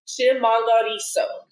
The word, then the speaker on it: Chimhangariso: Oh, I am so sorry, Chimhangariso. One more time. – Can we get that pronunciation one more time? – It's Chimhangariso. – Chimhangariso.